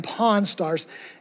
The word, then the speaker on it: Pawn Stars: Have you ever seen this TV show called Pawn Stars? Now make sure you're hearing me say that correctly, okay? Because I don't want you to actually see the other show that... Pawn, P-A-W-N, Pawn Stars.